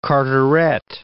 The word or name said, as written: Carteret